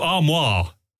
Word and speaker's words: armoire: Is that from armory? – No, armoire. Armoire. – How do you spell that? – A-R-M-O-I-R-E. Armoire is like French for wardrobe, which this is not.